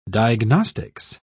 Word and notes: diagnostics: British English

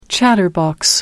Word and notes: chatterbox: American English

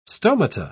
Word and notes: stomata: British English

